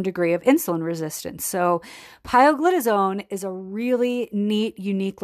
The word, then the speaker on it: pioglitazone: And that one is called pioglitazone.